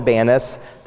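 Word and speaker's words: Banias: Indeed, welcome to Banis, the Arabic pronunciation for Pan. Arabic evidently has no P sound.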